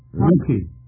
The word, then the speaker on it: Comiskey: The first is Charles Comiskey or Comiskey. Mr. Chairman, how do you pronounce it? – Comiskey.